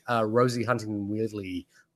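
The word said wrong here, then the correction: Whiteley: Wheatley, uh, I'm gonna, I, I butchered that. I Wheatley, Whitley, um, Whitley, yeah.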